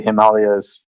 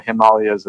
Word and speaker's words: Himalayas: And it is derived from the tradition of the Upanishads, which are the original Vedantic texts that came out of the Himalayas 5,000 years ago. So there's a flow of knowledge that started in the Himalayas, or Himalayas as Americans say here, that goes down to present day.